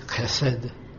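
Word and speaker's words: chesed (חסד): But this is chesed, which is chesed. Chesed. It's like your uncle in the morning when he clears the road. Because this word is a funny word. But we, in English, we just pronounce it in the easy way, chesed.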